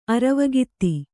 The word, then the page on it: ಅರವಗಿತ್ತಿ: Kannada